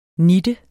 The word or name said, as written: nitten